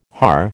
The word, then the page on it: haar: American English